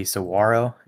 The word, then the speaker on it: saguaro: The technical pronunciation would, in like the native word, would be saguaro. You don't actually pronounce the G, but pretty much everybody pronounces the G.